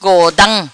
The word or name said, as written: Godam